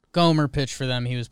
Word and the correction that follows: Gomber: part of the Arenado trade, but Gomer. We say that wrong a lot. Everyone says. – Sure. – Gomber. I think you pronounced it. – Gomber. – Somber about it.